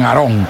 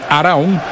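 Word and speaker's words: Arão: Los brasileños dicen Arón, pero bueno, nosotros tal como se escribe, Arao, gran jugador, el de hombre de la melena, tiene el número 15.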